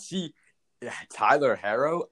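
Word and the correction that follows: Herro: I mean, Hero. Pronounce his name right first.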